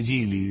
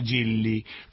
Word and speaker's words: Gili: Entonces se pronuncia Gili directamente, no Gilli, porque Gilli es con doppia L.